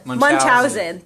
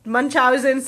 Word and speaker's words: Munchausen: Munchausen. – Thank you. Munchausen syndrome by proxy.